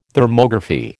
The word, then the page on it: Thermography: American English